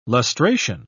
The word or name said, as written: lustration